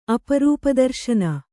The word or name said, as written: ಅಪರೂಪದರ್ಶನ